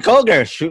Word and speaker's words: Koger: Andrew, do I say it Cougar or Koger? – Koger. – Okay, because some names have a German pronunciation to them, right? So at least you're not a cougar. That's a good thing. Again, welcome to the show, Andrew Cougar. I mean, Koger.